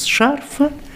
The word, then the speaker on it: шарфа: Будем говорить без шарфа, с шарфом, о шарфе. Но я хочу вам вот что сказать, что ударение на флексии, ну вот на флексии, лингвистически выражаясь, на окончании, такое подвижное ударение производит впечатление не очень культурного человека.